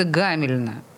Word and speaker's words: Гамельна: И в его рамках Петербургу был показан любопытный фильм, который называется «Куда уходят дети из города Гамельна». Правильно ли я ударение ставлю? – Да, все верно.